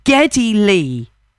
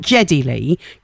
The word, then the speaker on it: Geddy Lee: Geddy Lee. Not Jeddy Lee. Geddy Lee.